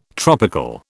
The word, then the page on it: tropical: British English